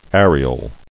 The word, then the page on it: Areole: ar·e·ole; A"re*ole